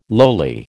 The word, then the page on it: lowly: American English